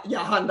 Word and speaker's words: Jahan: Jahan Dotson. it's a soft J. Soft J for Jahan Dotson from Penn State, who admittedly was damn good.